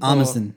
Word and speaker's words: Armisen: Is that how you pronounce his last name? – Armisen? – Armisen. – Yeah.